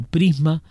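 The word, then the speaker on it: PrSM: Las características del arma, las explosiones y los daños son consistentes con el misil de ataque de precisión que se pronuncia como Prisma, diseñado para detonar justo encima de su objetivo y lanzar pequeñas bolitas de tungsteno hacia afuera.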